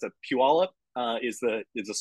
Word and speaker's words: Puyallup: Um, like, are you, are you from Paliup or Tacoma? So so yeah, it's it's called, it's pronounced Puyallup Oh, sorry, Puyallup. Yeah, sorry, Puyallup.